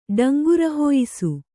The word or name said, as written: ಡಂಗುರಹೊಯಿಸು